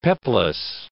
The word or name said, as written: peplos